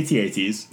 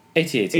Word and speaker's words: AT-AT: So they go fight the AT-ATs. – Yes, the elephant walkers. – Yeah. – How do you pronounce it? – AT-AT.